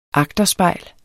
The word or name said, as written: AGTERSPEJLE